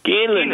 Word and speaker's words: Geelan: G-E-E-L-A-N. – And how do you say it? – Galen. – Galen. Galen.